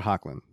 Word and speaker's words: Hoechlin: Tyler Hochner. However you say his name. – Tyler Hoechlin. So yeah.